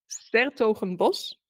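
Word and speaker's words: 's-Hertogenbosch: S-Hertogenbosch? – S-Hertogenbosch. – Ah, okay. Yeah, I said I'm going to butcher it. – But usually don't bother with it. They just say Den Bosch.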